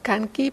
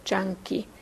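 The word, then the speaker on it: Canki: Se escribe C-A-N-K-I, C-A como Kanki, pero se pronuncia Chanki.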